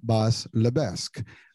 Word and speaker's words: Lebesque: So without further ado, I have to introduce our guest today, Bas Lebesque.